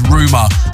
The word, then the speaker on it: Ruuma: My name is Ruma. R-U-U-M-A is how you spell it.